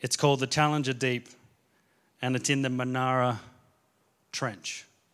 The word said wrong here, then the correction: Mariana Trench: I think I said it wrong. Did I say it? Mariana Trench, thank you.